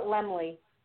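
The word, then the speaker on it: Laemmle: tomorrow, Thursday, April 27th, 7 o'clock, and that's taking place, I believe it's Lemel's Royal Theater. I might be butchering the name. – Isn't that how you pronounce it? – I think they call it Lemley.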